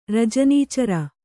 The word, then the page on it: ರಜನೀಚರ: rajanī cara